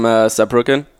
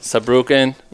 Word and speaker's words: Saarbrücken: So I'm from Saarbrücken. – Okay. – So Saarbrücken.